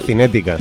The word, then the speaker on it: cinética: ¿Cinérdica lo has acertado esta vez? – ¿Cómo? – ¿Cinérdica? – A la cinética, sí.